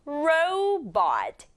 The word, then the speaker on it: robot: 영어로는 row 그리고 bot 붙여서 robot 이렇게 발음하는 게 정확하다. row 부분 써볼게요. r, o 만나서 row 그리고 b, o, t 만나서 bot. 강조는 robot 앞에다 주면 돼요.